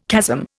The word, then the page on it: chasm: Indian